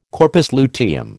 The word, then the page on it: corpus luteum: American English